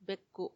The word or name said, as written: beku